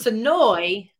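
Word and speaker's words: Senoia: How do you pronounce where you guys are from? Sinowa, Georgia? – It's Sinoy. – Sinoy. – Almost like there's two E's on the end instead of the IA.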